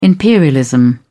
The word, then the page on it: imperialism: British English